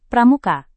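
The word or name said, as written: prediksi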